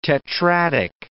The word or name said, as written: tetradic